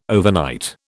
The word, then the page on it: overnight: British English